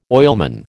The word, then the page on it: oilman: American English